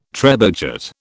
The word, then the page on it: trebuchet: British English